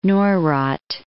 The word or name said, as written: Neurath